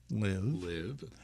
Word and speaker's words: LIV: Starting with its name, which, when pronounced, rhymes with give. – Live, but is actually the Roman numeral for 54, the number of holes played in each tournament.